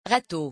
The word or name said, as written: rateau